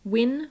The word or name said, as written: win